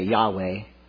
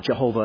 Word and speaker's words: Yahweh: If you want to pronounce it, in English, we'd say Yahweh. Jehovah would be another pronunciation of the same thing.